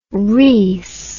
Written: reese

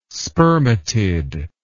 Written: spermatid

